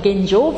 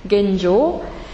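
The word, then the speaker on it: Genjō: Koan ist ja Prüfungsfrage im Zen, und Genjo aussprechen, Genjo, nicht Genjo, sondern Genjo wie Englisch, englische Leseweise. Genjo ist ein Zen-Terminus und bedeutet aktuell werden.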